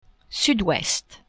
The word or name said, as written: sud-ouest